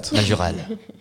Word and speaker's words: Majorelle: How do you pronounce that in English? Majorelle? – Majorelle. Majorelle. – Majorelle. Or you pronounce it with a French accent.